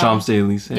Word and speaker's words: Champs-Élysées: Champs-Élysées.